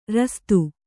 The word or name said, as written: ರಾಸ್ತಿ